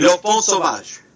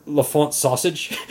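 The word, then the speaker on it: L'Enfant Sauvage: I don't want Le Bon Sauvage. – Okay. – Means wild child. – Okay, you know, for whatever reason, I was reading it as Le Font Sausage, and so I thought it was a humorous title.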